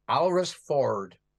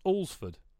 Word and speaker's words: Alresford: How would you pronounce How would you pronounce where I grew up, which is spelled A-L-R-E-S-F-O-R-D? – Alresford Ford. – No. – Alresford. – And it's also, this is the beautiful, it's pronounced Allsford.